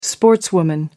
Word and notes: sportswoman: American English

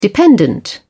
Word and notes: dependent: British English

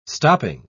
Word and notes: stopping: British English

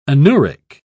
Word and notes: anuric: British English

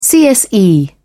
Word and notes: cse: British English

